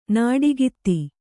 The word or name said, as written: ನೋಟಗಾತಿ